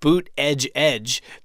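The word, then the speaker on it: Buttigieg: he had people on stage leading chants of boot edge edge to help people pronounce his name.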